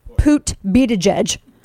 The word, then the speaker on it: Buttigieg: And again, I'm going with the Larry O'Donnell of MSNBC's pronunciation. Thank you. Thank you, Mr. Kane. Mr. Radio Kane. Thank you. Pete Buttigieg.